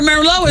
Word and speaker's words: Merlot: Yeah, remember Merlot is, and that T is silent the T on the end of Merlot.